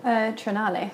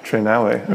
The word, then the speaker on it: Triennale: the Oslo Triennial. – Mm-hmm. – Is that how you say it? – Triennale. – Triennale, okay. The Oslo Triennale.